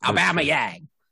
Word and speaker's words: Aubameyang: So you have to remember, you're talking to British people. They have their way of pronouncing it. Aubameyang. That's how you got to pronounce it.